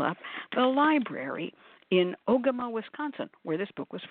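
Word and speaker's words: Ogema: And my first question was, how do you pronounce Ogama, which I didn't know. And she said, Ogama, it's an Ojibwe word.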